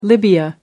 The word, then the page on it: libya: American English